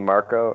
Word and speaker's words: Marko: And I am Ryan JP Marco, MARKO.